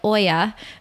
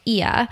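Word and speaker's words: Oia: I used to call it Oya, but Rolf Potts kindly let me know that it's pronounced Ia.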